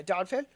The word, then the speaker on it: Donphan: Is it Donphan? Donphan? How do you pronounce his name? – Yeah, Donphan.